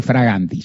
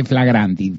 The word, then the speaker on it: flagranti: Primero que no se dice fraganti, se dice flagranti.